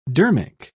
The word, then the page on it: dermic: British English